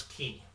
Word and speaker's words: Chi: So it's actually called Kingdom Hearts Key. It's spelled C-H-I, and it's pronounced key, and that's why when you hear they talk about the Keyblade, because, come on, Nomura!